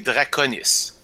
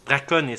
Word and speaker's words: Draconis: C'est Draconis. – Draconis, excuse-moi. C'est à cause que moi, actuellement, j'ai ton image Hangout, qui est un blason avec un dragon. Fait que ça m'a influencé dans la prononciation.